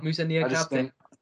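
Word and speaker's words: Moussa Niakhaté: And I really like Mainz's Musa Nikate. Definitely mispronounced that, but I probably did a better job. – Now you got it spot on.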